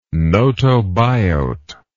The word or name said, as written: gnotobiote